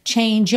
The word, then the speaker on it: change: But if you change your mind, então, but if, aqui juntou com rarara, change your, lembra que é de change, é mudo.